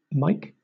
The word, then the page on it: mike: British English